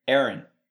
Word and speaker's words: Eron: Aaron. He calls him Aaron. – Okay, so it's spelled Eron. Like Eron.